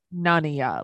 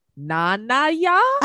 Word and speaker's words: Nonya: It's Nonya, like it's none of your business. These two morons kept I know. – Saying like, Nonya. Nanaya?